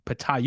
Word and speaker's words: Ptah: Initially thought the P might be silent. But then I realized, you know, you've got, because you see words like pneumonia, Ptolemy, you know, my new favorite, psychopomp. They have these nice silent P's at the beginning. But those are, of course, all Greek words. And Ptah is Egyptian, where apparently the P is pronounced. – It is pronounced, yes.